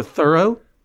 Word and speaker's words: Thoreau: About the great, well, arguably great, certainly famous, Massachusettsian misanthrope Henry David Thoreau, or Thoreau, as I learned to my deep surprise, is how it's pronounced, according to NPR, this past summer when his 100th birth or death anniversary came around.